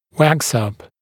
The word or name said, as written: wax-up